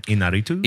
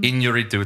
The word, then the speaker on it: Iñárritu: I'm nervous to say the last name. Iñárritu. – Iñárritu. It's got like that accent above the end that makes it like a sliding. – Iñárritu.